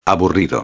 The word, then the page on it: aburrido: espanhol